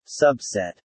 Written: subset

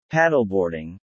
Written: paddleboarding

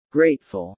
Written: grateful

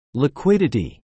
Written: liquidity

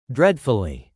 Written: dreadfully

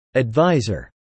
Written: adviser

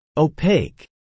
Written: opaque

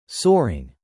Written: soaring

